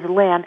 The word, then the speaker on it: Lin: My email is Lin, and my first name is spelled L-I-N.